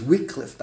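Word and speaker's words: Wickliffe: Wycliffe, Ohio, if I'm pronouncing it right. I'm so mixed up already. Wycliffe, Wycliffe. I think it's Wycliffe, I was, according to the people in Cleveland.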